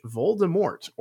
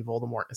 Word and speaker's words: Voldemort: Fun fact, in accordance with French pronunciation, Rowling revealed that the last T in Voldemort is silent, meaning we've been saying it wrong all these years.